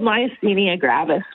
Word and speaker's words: myasthenia gravis: Yeah, so it's called myasthenia gravis, which actually just means, in really plain language, grave or deadly muscle weakness.